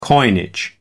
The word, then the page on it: coinage: American English